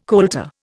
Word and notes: colter: Indian

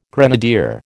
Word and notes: grenadier: American English

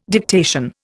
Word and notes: dictation: Indian

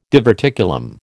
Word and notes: diverticulum: American English

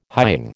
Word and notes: hying: American English

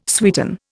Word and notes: sweeten: Indian

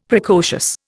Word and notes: Precocious: Indian

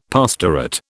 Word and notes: pastorate: British English

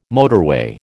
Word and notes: motorway: American English

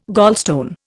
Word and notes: gallstone: Indian

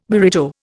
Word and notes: burrito: Indian

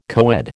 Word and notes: coed: American English

